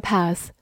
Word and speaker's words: path: path，p a t h，path，字母 a 发长音 r。 t h 发咬舌音，path，美式发音字母 a 发大口 a，path 也是可以的。它是一个名词，表示小路或者小径。比如说 block the path，就是我们说的挡路，block 就是封锁、阻挡的意思。